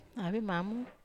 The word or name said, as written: avemamo